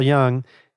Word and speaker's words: Jung: Jung, yeah. Don't pronounce the J. – The famous... no, it's okay. Yeah, so it is spelled J-U-N-G if anyone wants to go read up on some Carl Jung.